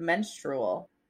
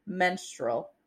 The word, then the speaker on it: menstrual: M-E-N-S-T-R-U-A-L. – Google says three syllables in menstrual.